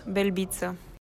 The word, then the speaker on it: Belbita: Her last name is spelled B-E-L-B-I. T-A. So it looks a lot like Belbita on paper. Cody Merrow, of whom am I speaking? – Well, I feel like you said that. Now I'm going to say it incorrectly, but I think it's Diana Belbita.